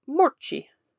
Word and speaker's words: Moortje: So, while Mauchi brought me to this topic, I would like to start by talking about Anne Frank's own cat, who went by the name Morchi. And it's spelled M-O-O-R-T-J-E.